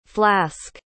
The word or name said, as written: flasque